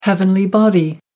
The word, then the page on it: heavenly body: American English